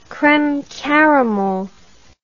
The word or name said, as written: creme caramel